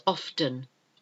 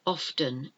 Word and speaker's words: often: It is often said incorrectly as often. Often. It's often. Often. O-F-T-E-N.